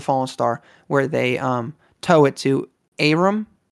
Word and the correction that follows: Eiram: Aram, how do they pronounce it in the book? – Eiram. – Eiram, thank you.